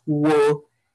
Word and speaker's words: Nwokeji: My the, the best way to pronounce this is Wokiji. Wokiji. – Okay. – Yes, it's spelled N W O K E J I, or you can pronounce it W O O K E E G E E. Wokiji.